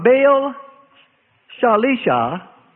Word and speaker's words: Baal Shalisha: And there came a man from Baal Shalisha, that's how you pronounce that,